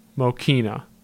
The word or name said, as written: Mokeena